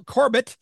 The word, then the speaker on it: Corbett: And our newest member, Bill Corbett. Also, I was pronouncing it earlier. I think he might have some French in him, but Corbet. That is the proper French pronunciation, but Bill Corbett is here with us and so excited about it.